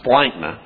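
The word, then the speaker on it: splanchna: The word translated tender feelings, this splankna, this is how you pronounce it, splankna, that refers to the organs of your body that are found within the trunk cavity.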